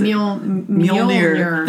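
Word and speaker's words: Mjölnir: She destroys Mjolnir. I can never pronounce it. – Mjolnir.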